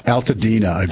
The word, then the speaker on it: Altadena: Altadena.